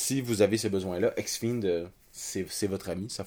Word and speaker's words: HexFiend: Xfind, alors c'est. Attention la prononciation, c'est H-E-X-F-I-E-N-D. Oui. Voilà, donc euh, c'est pas F-I-N-D ou quelque chose d'autre, c'est F-I-E-N-D. – Non, c'est pas friend non plus.